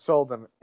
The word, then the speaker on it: Schuldiner: Chuck Schuldiner, I think it's pronounced. – Yeah, I thought it was Schuldiner. – Yeah, it's Schuldiner.